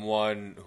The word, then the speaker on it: ONE: Do they pronounce it one or is it O-N-E? – It's O-N-E, but they're one. Yeah, they pronounce it one. Like S.H.I.E.L.D.